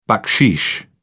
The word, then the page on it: baksheesh: British English